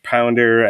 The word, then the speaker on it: Pounder: You have CCH Pounder as the captain. I think that's how you pronounce her name. It's, yep.